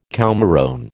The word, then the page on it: coumarone: American English